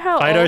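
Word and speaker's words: Fidough: that's made of like bread dough, and it's spelled Fido, as in dough.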